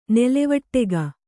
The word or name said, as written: ನೆಲೆವಟ್ಟೆಗ